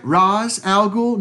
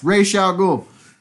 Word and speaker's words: Ra's al Ghul: Is I saying this right? Ra's al Ghul? No, bitch, you're not saying it. You didn't say it right. It's Ra's al Ghul.